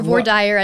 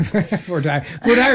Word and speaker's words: voir dire: dire or voir dire, as we say in Arkansas. It depends on where you're from. – Voir dire is easier for me to pronounce, so I'll go with that.